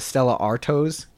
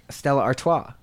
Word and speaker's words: Stella Artois: He calls it a Stella Artois. – And what is it really? – Stella Artois. But he pronounces it how he sees it.